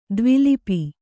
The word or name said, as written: ದ್ವಿಲಿಪಿ